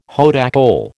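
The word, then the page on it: haute école: American English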